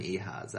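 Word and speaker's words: Ehasz: The other co-creator, Justin Richmond, was actually a co-director on Uncharted 3, but also the directorial team has come from Aaron Ehaz, actually. – It's Ehaz? – Yeah. – Okay, is that the correct pronunciation?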